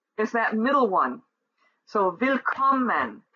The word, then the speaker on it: Willkommen: Willkommen, Rumänien have 3 syllables, and the middle syllable is accentuated.